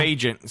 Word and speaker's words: Bagent: I like Bagent. That is how you say his name. – Yeah. – Bagent.